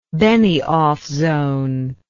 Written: Benioff zone

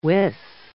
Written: wis

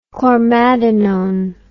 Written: chlormadinone

